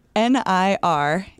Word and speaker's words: Niraparib: NIRAPARIB. – Niraparib. – Niraparib. – Actually got it pretty spot on. Niraparib.